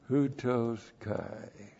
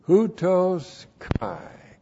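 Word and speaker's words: Houtos Kai (οὕτως καί): Houtos Kai. H-O-U-T long O-S K. Oop. No, look at that. Using Greek. Houtos Kai.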